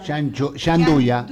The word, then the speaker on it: gianduia: La gianduia, me cuesta pronunciar. – Gianduia.